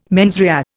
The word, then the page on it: mens rea: Indian